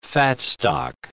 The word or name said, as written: fatstock